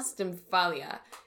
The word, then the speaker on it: Stymphalia: Stymphalia, which is where they get their name.